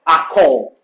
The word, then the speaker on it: achor: Or back part is the Hebrew word akor. Akor. A-C-H-O-R. Just like you're pronouncing ankle. Akor.